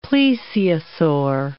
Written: plesiosaur

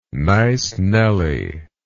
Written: nice nelly

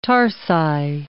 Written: tarsi

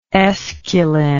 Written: aesculin

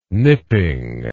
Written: nipping

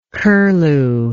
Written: curlew

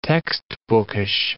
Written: textbookish

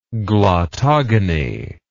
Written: glottogonic

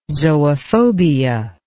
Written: zoophobia

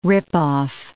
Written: rip-off

